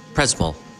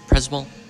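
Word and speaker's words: Przemyśl: It's Przemysl. Przemysl. This town where we are. – This Przemysl. – Przemysl. I'm sorry. Przemysl. I pronounce it completely different.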